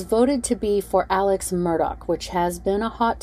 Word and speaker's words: Murdaugh: I have heard their last name pronounced Murdaugh and Murdaugh. I heard Buster pronounce it as Murdaugh in court, so that's how I'm going to pronounce it.